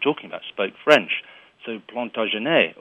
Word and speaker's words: Plantagenet: Is it Plantagenet or it depends on part of the country? – Now we tend to say Plantagenet, but of course you're closer in your pronunciation to the original,